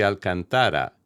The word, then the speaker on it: Alcantara: It's simply Alcantara, and must be pronounced emphasizing the second-to-last or third syllable, whether or not you're pronouncing it in Castilian, English, Italian, or Portuguese.